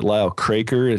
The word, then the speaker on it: Lyle Craker: So anyway, we were hoping that we could urge them to ask the tough questions, like, you know, Lyle, is it Lyle Craker? Is that how you say his name? – Mm-hmm.